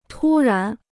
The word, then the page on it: 突然: tū rán